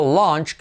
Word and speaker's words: lunch: Y este sonido lunch, que es como aparece es en fonética, es como una A así, pero sin la barrita de abajo, lunch.